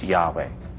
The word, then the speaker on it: Yahweh: but their English equivalents would look like a J-H-W-H, without any vowel, with some markings there to indicate where the vowel went. So those who know the Hebrew language best say that the closest pronunciation was Yahweh.